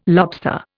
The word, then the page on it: lobster: Indian